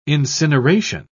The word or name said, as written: incineration